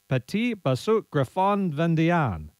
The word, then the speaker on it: Petit Basset Griffon Vendéen: The breed, for the first time ever, is spelled Petit Basset Griffon Vendéen, but pronounced something like Petit Basset Griffon Vendéen.